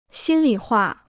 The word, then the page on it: 心里话: xīn li huà